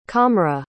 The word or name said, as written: qamra